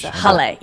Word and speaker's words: jale: J is with a, sounds like an H. – It's jale, yes. Jale.